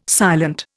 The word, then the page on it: silent: Indian